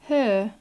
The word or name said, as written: her